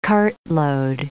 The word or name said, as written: cartload